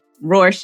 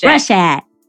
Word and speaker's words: Rorschach: Roar, like roar like a lion. And roar. Shack, like Shack. Shaquille. Rorschach. – Rorschach. – Rorschach. Roar. Shack. – Roar. Shack. You know, I was supposed to be hooked on phonics and I did the wrong stuff in there anyway, so.